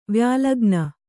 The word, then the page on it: ವ್ಯಾಲಗ್ನ: kannada